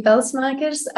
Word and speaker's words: Pelsmakers: You actually pronounced my surname correctly. so, so, I'm Sofie Pelsmakers.